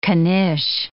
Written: knish

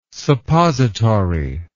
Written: suppository

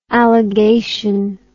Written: alligation

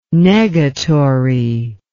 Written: negatory

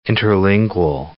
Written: interlingual

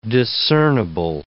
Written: discernable

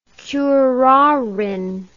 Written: curarine

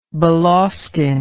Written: balaustine

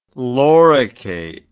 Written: loricate

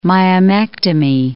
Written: myomectomy

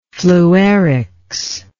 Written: fluerics

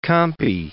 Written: Campi

